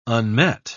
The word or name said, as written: unmet